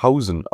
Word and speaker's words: Huijsen: dass er Huijsen ausgesprochen wird.